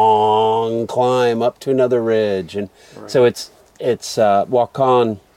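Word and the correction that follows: Waukon: I say walk-on. Walk-on? How do you say it? – Wakan.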